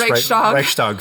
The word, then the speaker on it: Reichstag: It's pretty close. Reichstag fire.